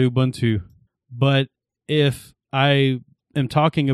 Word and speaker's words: Ubuntu: Speaking of Ubuntu, I know how to pronounce it.